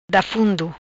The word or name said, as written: Da fundo